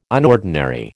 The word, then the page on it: unordinary: American English